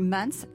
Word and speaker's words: Maans: Vous êtes le fondateur de Mans, alors on dit Mans, ça s'écrit M-A-A-N-S et on entend la musique parce que Mans ça signifie Music as a New Sense.